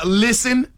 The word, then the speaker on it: listen: Listen is another one where the English words has a silent letter. – Silent and listen? – T. Listen. – Listen. – You don't hit no T in listen.